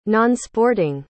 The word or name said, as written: nonsporting